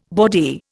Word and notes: bawdy: Indian

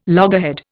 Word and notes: loggerhead: Indian

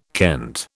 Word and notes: kent: British English

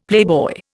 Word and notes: playboy: Indian

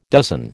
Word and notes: dozen: American English

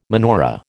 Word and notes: menorah: American English

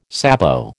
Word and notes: sabot: American English

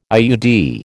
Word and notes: IUD: American English

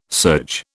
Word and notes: serge: British English

